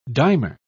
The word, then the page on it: dimer: British English